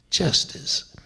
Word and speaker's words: Gestas: D-I-S-M-A-S, Dismas. Dismas was the repentant thief. On the left hand was Justice. Now it's spelled G-E-S-T-A-S, but the G is pronounced like a J, and it's Justice.